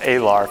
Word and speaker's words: Alar: There's some talks by, it's Father Chris, does he say Alar? Is that how you pronounce it? Alar, Father Chris Alar.